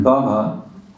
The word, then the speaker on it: Bhava: And Bhava, it's important, this is short A, it's not long A, it's not Bhava.